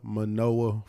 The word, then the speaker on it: Manoah: But my YouTube channel is Manna Manoah 405. So Manna is spelled M-A-N-N-A, then space M-A-N-O-A-H, and the 405 is connected to Manoah.